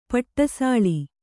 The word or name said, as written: ಪಟಸಾಲೆ